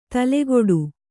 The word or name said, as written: ತಲೆಗೆಡು